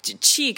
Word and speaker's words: qi: So qi can be qi or chi, depending on how you want to pronounce it.